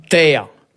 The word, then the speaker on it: taer: Terre. – Ça s'écrit comment ? – T-A-E-R.